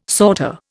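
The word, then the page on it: sauté: Indian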